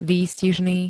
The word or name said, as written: výstižný